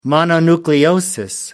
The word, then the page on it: mononucleosis: American English